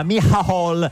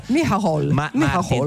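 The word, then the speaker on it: Micheál: No, perché essendo irlandese uno pensa che si pronunci all'inglese, si chiama invece è scritto tipo Michael irlandese. – Martin, invece si chiama Michal. – Michal. – Tipo alla toscana un po', però insomma, e di cosa avete altro parlato con il presidente iraniano?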